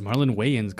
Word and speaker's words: Marlon Wayans: Marlon Wayans? I don't know how. – It's Marlon Wayans. Come on, bro.